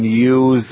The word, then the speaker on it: yew: If it doesn't have to have any flowers, you can use an upright U. And it's spelled Y-E-W. So an upright U.